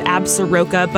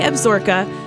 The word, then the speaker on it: Absaroka: It's pronounced a little weird. You would expect it to be said Absaroka, but it's actually Absaroka, and the actual word is in the crow tongue and means children of the long-beaked bird.